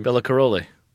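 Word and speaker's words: Béla Károlyi: Bella Caroli. – Bella Caroli.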